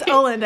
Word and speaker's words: Åland: I think it's Åland. I looked it up. It's an A with a little circle above it, and it's pronounced O.